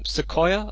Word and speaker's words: Sequoyah: It was called the state of Sequoyah, I, th- I, believe. That's correct. How do you pronounce that? – Yeah, that's that's correct pronunciation.